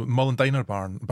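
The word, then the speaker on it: Molendinar: I've never known how to say it as well. So Mullandiner burn, that's it, the Mullandiner.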